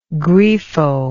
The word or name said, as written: griffo